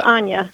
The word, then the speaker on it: Anya: That's pretty close. It's Anya. – Anya. Okay, Anya. That's a beautiful name. How do you spell that? – That's A-N-Y-A.